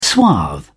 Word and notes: suave: British English

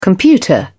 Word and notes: computer: British English

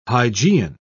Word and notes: hygeian: British English

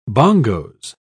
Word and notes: bongos: British English

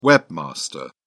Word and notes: webmaster: British English

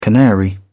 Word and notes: canary: British English

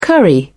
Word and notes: curry: British English

